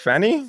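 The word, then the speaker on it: Fani: Is it pronounced Fannie Willis or Fonnie Willis? – I think, what did Trump say? It's like your ass, like Fanny?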